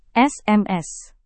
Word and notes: sms: Indonesian

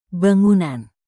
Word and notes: bangunan: indonesian

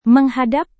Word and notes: menghadap: Indonesian

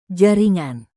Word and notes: jaringan: Indonesian

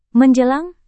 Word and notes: menjelang: Indonesian

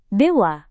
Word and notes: dewa: Indonesian

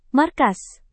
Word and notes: markas: Indonesian

